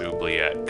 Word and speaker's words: oubliette: It's actually pronounced oubliette because it's an anglicization of a Middle French word. And I can show you the IPA.